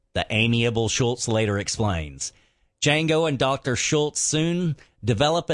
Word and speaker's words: Django: Throughout their travels, Dr. King Schultz learns that Django, the D is silent, hillbilly, is a married man.